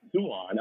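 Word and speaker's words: Siuan: And I go, so it's not Swan, it's Suan.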